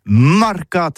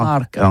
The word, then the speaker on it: marcat: Eh bien, d'ici à toutis, le mercat de l'Uni ou le mercat des rebelles, mais en gascogne toulousano, c'est prononcé sur les marcat, avec un A, marcat.